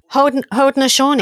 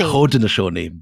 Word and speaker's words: Haudenosaunee: and you pronounce it rather than me because I'll make a mess of it. – Haudenosaunee. – Haudenosaunee. Beautifully done.